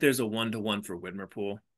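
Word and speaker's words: Widmerpool: I saw some interview with him where he's always, he was constantly asked who's Winder, I can't say his name, Widmer Pool. – Widmer Poole.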